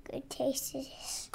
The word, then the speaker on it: Cretaceous: How do you say it? – Cretaceous. – I was not that far off. The way you reacted was like I couldn't have been more wrong. – Cretaceous. – It's Cretaceous. I put an extra S in.